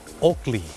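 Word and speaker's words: Oakley: โอกลี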